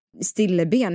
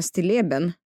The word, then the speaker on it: stilleben: Jag tänkte ju ta lite sådär snabb Moder Teresa att jag hade lärt mig att det inte alls uttalas stilleben utan stilleben.